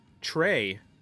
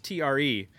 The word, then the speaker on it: Tre: So Jay, we're doing another month with Studio, where instead of working with the Regent, we're working on the working with the Studio Tray, I believe is how it's pronounced, TRE, which are the inner ear headphones, and we're gonna be talking about those later.